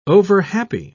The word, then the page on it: overhappy: British English